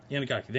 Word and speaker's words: Inagaki: Inagaki, thank you.